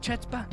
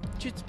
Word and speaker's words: chutzpah: Chutzpah. That's it. The C is silent.